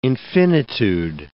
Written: infinitude

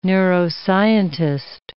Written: neuroscientist

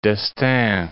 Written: Estaing, d'

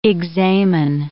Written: examen

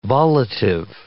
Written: volitive

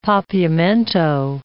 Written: Papiamento

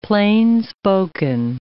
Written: plainspoken

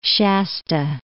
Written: Shasta, Mount

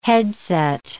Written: headset

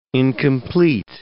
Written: incomplete

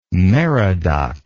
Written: merodach

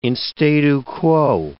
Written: in statu quo

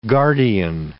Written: guardian

